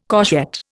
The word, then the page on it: courgette: Indian